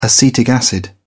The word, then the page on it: acetic acid: British English